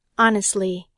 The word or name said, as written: honestly